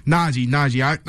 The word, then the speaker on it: Najee: It's Najee or Najee, right? – Najee.